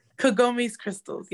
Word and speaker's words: Kagomes Krystals: Kagomes Krystals, yep.